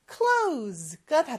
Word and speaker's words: close: close가 닫다인데, 요 발음 중요하다고 그랬죠. 끝에 S 발음은 Z 발음처럼 close. 요렇게 발음해야 된다고 했고요.